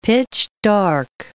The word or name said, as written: pitch-dark